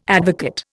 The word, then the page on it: advocate: Indian